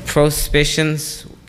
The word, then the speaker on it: Prospicience: Prospicience, can you use that in a sentence? – Qualities associated with leadership usually include prospicience.